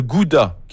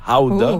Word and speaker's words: Gouda: Ou 3, le gouda, qu'il faut prononcer d'ailleurs howda. – Ooh, howda. – Howda? – Oui, en néerlandais. C'est howda. Oui.